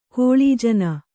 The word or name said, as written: ಕೀಳುಜನ